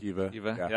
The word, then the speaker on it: Sceva: how do we say it, Sceva? – Sceva, yeah.